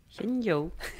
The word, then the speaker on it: Shenzhou: Parlavamo anche della capsula Shenzhou 16 mm, perché porta tante novità. – È una aspetta, aspetta, facciamolo pronunciare alla vero. – Vai. – Shenzhou.